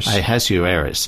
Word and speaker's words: Ahasuerus: It says, in the first year of Darius, the son of Ahasuerus, how do you say that one? – Ahasuerus, of the lineage of the Medes, who was made king over the realm of the Chaldeans.